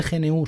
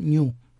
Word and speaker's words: GNU: Vale, los puretas me diréis que no se dice GNU, se dice GNU.